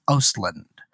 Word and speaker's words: Östlund: Now, the umlaut, that indicates that I pronounced the letter. – Yeah. – So it's Östlund. – I had a barbarian named Mothar. I spelled M-O with umlauts, T-H-A-R.